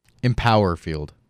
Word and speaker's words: Empower Field: at Davali and at Empowerment Field? What's it called? – Empower Field. – Not Empowerment? – It's Empower Field. – Really? – Yeah. You've been saying it wrong this entire time.